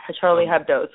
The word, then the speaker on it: Charlie Hebdo: For example, you see something like war, or you see something like at Charlie Hebdo. I don't know how to say it. Is it Charlie Hebdo? – Charlie Hebdo. So you see something like that and you say, wow, what is really happening here?